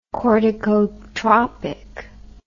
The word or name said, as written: corticotropic